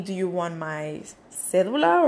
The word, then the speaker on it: cédula: But here, when you talk about an ID, it's called cédula. Cédula, right? So cédula is C-E-D-U-L-I-A. C-E-D-U-L-A.